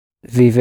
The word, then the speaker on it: Wie: W-I-E, the E sound.